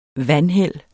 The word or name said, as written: vanheld